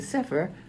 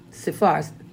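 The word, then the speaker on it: Sephar (ספר): And I know in my Sephar, many people pronounce it Sefer, or some people call it a Jewish Bible. No, excuse me, Hebrew Bible, they call it. But it's Sephar.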